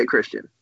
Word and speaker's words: Christian: Because although my name has 9 letters, it's only 2 syllables. – Right. – And I just don't think it should be that difficult to say Christian.